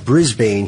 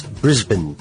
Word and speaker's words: Brisbane: While Americans always pronounce it Brisbane, he says, it's Brisbane. All right.